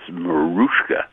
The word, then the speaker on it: Mariska: She said, by the way, she likes her name, even though it's spelt with an I, she refers to herself as Mariska.